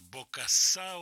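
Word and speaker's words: Bokassa: Смотрю, группа Бокасса называется. Бокасса, ударение французское.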